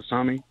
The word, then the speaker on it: tsunami: Am I saying that right? Yeah. Tsunami? – Tsunami. – Tsunami. – Tsunami. Yes. – Yeah. – Silent T.